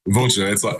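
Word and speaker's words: Vâlcea: Vulture. V-U-C-H-A. That's how I tell people. V-U-L-C-H-A.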